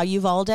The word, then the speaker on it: Uvalde: Uvalde. – Yeah, Uvalde.